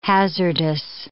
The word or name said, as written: hazardous